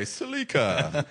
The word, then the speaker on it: Celica: Everyone but there's ignoramuses from America said it the correct way. Celica.